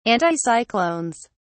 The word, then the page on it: anticyclones: British English